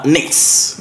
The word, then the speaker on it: Nix: Pero bueno, pasemos a otra banda de Medellín que clasificó en la categoría hardcore y es la banda Nix, que se escribe N-I-X.